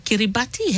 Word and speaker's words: Kiribati: How for you pronounce himna Kiribati? Himna me just tell him no more a while ago. Him pronounce the same Kiribati. Yeah. So the T-I in Kiribati him pronounce is S-I.